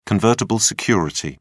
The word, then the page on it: convertible security: British English